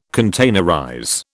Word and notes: containerize: British English

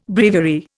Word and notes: breviary: Indian

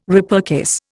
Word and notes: repurchase: Indian

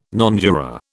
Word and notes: nonjuror: British English

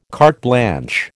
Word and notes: carte blanche: American English

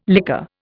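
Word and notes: lycée: Indian

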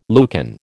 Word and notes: Lucan: American English

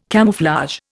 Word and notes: camouflage: Indian

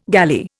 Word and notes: galley: Indian

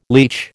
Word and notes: leach: American English

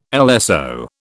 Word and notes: LSO: British English

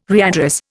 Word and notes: readdress: Indian

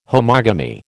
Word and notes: homogamy: American English

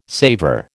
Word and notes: savour: American English

